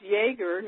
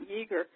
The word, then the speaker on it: Yeager: And I says, no, it's Yeager, not Yeager.